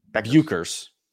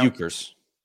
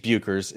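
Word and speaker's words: Bueckers: Paige Buchers. No. Buchers. – Buchers? Is that it? – Paige Buchers is how you pronounce the standout player from UConn, who will soon to be in 2025 the first pick of a WNBA draft.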